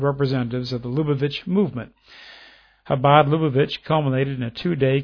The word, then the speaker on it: Chabad: It says the White House to Chabad, and it's C-H-A-B-A-D, but pronounced Chabad and Lubavitch.